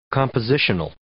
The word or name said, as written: compositional